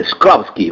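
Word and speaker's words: Shklovsky: Viktor Slovsky. – Sì, Sklovsky, vai. – Sklovsky. – Non parlo bene il russo. – Perché non riesco a... – Sì, però è Viktor Sklovsky.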